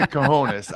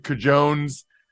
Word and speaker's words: cojones: I already got the cojones. I'm good with the cojones now. I don't know why I said cajones.